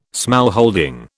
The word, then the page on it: smallholding: British English